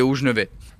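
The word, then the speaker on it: Hauts-Geneveys: Ouais, et puis les Hauts-Genevais ou les Hauts-Genevais ? – Moi je dis les Hauts-Genevais.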